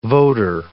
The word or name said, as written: voter